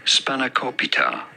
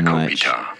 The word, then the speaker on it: spanakopita: How do you go about pronouncing it? Spanakopita. – A mix of the two. – Dude, on the third on the co syllable. I love this guy so much. Spanakopita.